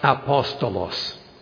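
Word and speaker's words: apostolos (ἀπόστολος): The Greek word is simply pronounced apostolos, and the English word comes directly from it, apostle.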